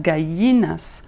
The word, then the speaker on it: gallinas: Puis moi, je pensais que c'était Cuando las galinas tengas, tengan dientes, mais c'est cuando las gallinas tengan dientes.